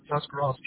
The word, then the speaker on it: Tuscarawas: It'll be in Tuscarawas County, Ohio.